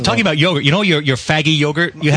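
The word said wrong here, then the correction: Fage: It's not faggy. – It's spelled F-A-G-E. – It's faggy. It's faye.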